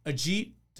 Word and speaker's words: Ajit: We are so incredibly fortunate to welcome in Ajit Dodani.